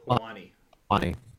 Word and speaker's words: Helwani: How do you say his name? Helwani? – Helwani.